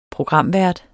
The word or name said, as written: programvært